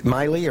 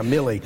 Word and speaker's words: Milley: is it Miley or Milley? – Yeah, I'm not sure. It ought to be, if it's not pronounced Milley, it ought to be. – Yeah, Milley. So, yeah.